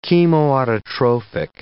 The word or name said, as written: chemoautotrophic